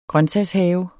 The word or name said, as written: grøntsagshave